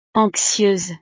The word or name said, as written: anxieuse